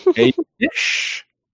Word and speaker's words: Eilish: Eilish